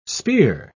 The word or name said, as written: spear